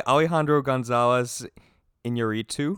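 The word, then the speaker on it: Alejandro González Iñárritu: it was done by Alejandro Gonzalez Iñárritu. – Yeah, that's good. You got it.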